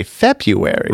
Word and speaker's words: February: I guess I remember as a kid, my teacher even saying like, yes, it's spelled February, but we say February.